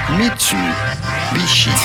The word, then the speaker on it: Mitsubishi: おはようございます。It is pronounced Mitsubishi.